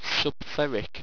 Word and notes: subtheric: owstwecian

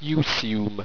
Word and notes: ewesume: Owstwecian

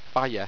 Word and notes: fiej: Owstwecian

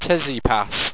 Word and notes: tezipas: owstwecian

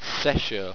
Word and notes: sesho: owstwecian

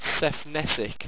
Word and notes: sephnessic: owstwecian

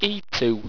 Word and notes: eatou: Owstwecian

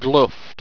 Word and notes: gluft: Owstwecian

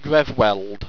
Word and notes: grevweld: Owstwecian

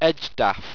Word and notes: edgdaf: Owstwecian

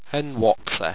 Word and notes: henwoxer: Owstwecian

